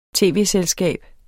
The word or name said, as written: tv-selskab